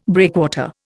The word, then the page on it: breakwater: Indian